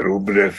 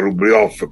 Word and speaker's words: Rublev: Sì, anche perché questi russi da voi citati vivono ormai, cioè Rublev, che poi in realtà si dovrebbe pronunciare Rublev, come un vecchio pittore di icone del Cinquecento, Andrei Rublev, su cui fu pure un grande film di Andrei Tarkovsky molti anni fa.